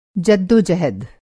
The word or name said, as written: जद्दोजहद